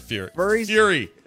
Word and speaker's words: Fury: So that's not furry. Fury. – Furry. I was going to say Furry, I was like, what has that got to do with anything? – I said it wrong. Fury statue.